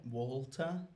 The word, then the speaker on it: Walter: But I say the L a bit. Walter. – Uh-huh. – Water, Walter. – That sounds pretty much the same to me. – Walter, water.